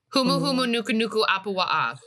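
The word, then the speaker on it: Humu Humu Nuku Nuku Apuwa'a (Humuhumunukunukuāpuaʻa): Humu Humu Nuku Nuku Apuwa'a.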